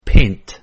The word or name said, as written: paint